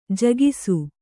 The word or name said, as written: ಜಾಗಿಸು